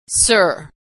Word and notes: sir: American English

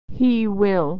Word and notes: he will: American English

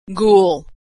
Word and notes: ghoul: g

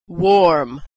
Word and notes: warm: American English

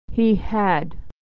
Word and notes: he had: American English

